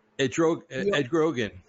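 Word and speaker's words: Erdoğan: Putin and how do you pronounce it? I always pronounce his name wrong. The Prime Minister of Turkey. Erdogan? How do you? – Erdogan. Yeah.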